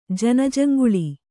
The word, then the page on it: ಜನಜಂಗುಳಿ: Kannada